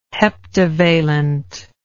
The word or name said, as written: heptavalent